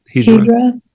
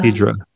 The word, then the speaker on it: Hijra: Like the ones in India, the Hijra in India. How do you say it? Am I saying that word right? – Hijra? Yeah. – Hijra.